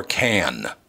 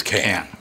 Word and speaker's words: Cannes: And now, is it Cannes or Cannes? – Cannes. – Yeah, it's Cannes. – So it is Cannes. – Cannes. – C-A-N-N-E-S is pronounced Cannes.